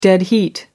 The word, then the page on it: dead heat: American English